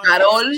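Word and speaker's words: Karol: Carol, stay with me. Roll the L, Carol. – She says, her name says Carol.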